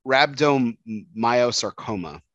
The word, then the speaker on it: rhabdomyosarcoma: It's uh, ra- rhabdomyosarcoma, which is a soft tissue cancer.